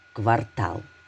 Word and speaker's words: квартал: Квартал. Некоторые уверены, когда речь идет о городском квартале, ударение следует ставить на второй слог, а когда о четверти календарного года – на первый.